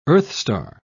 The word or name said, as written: earthstar